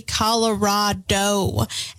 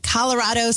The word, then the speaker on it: Colorado: And I'm very guilty of this because I say Colorado.